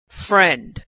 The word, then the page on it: friend: female